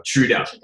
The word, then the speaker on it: Trudeau: Trudeau or Trudeau? And I'm not sure how to pronounce his name. – Trudeau. – Okay, Kevin Trudeau.